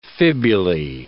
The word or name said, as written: fibulae